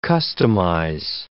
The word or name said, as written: customize